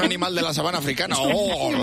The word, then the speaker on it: Google: Google también lo decimos mal porque la final no se dice E. Se dice Google.